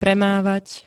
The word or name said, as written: premávať